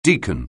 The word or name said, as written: deacon